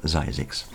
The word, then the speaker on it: Zzyzx: Die legendäre ZYZX, also ZZYZX Road, ausgesprochen ZYZX.